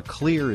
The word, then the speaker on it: Xlear: Clear is spelled X-L-E-A-R, and we talk about it on this program.